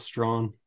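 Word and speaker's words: Straughan: It's either Strawn or Strahan. – Yeah, let's go with Strawn. Looks like maybe the GH is silent.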